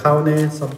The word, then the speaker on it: Jaune: How do they say it? – Like June. – June. – And like all the Spanish people also can't really pronounce my name. – Because the J there June, is silent. Jaune.